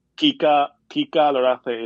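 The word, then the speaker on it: Kika Lorace: By the way, her name, Kika Larace, is a game of words. Obviously, it's a word game because that's how you say it's too hot. Like, que calor hace.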